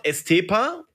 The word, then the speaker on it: Estepa: Dein Geburtsort Estepa, habe ich richtig ausgesprochen? – Ja, genau.